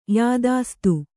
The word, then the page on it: ಯಾದಾಸ್ತ: yādāsta